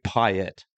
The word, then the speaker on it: Payet: And I thought we say Payet, as in the Ile de Réunion, where he's from. We do pronounce the T.